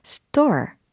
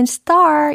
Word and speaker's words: stir: 졌다, 저가면서 섞다 라는 동사로 store, stir 이라는 동사, stir 이라는 동사로 시작해 보세요. 그럼 정답 공개! Stir the soup constantly. Stir the soup constantly. 이와 같이 만드시면 되겠습니다. 밤하늘에 있는 star 이게 아니라 stor이라고 발음이 됩니다.